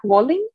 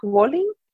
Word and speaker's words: Wolynn: Y el autor se llama Mark Walling. Se escribe M-A-R-K y el apellido es W-O-L-Y-N-N.